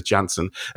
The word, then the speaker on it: Cansun: He's of Turkish origin, Turkish and German origin, and his last name is spelled Cansun, but he'd always correct everyone because the C is pronounced like a J. So I think Attila Jansson.